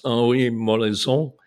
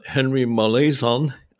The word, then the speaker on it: Henry Molaison: whose real name was Henri Molaison, or Henry Molaison, I think you could pronounce it. In Canada, you have two languages, of course.